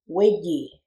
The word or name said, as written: wiji